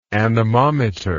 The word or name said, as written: anemometrically